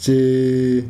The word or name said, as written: tshee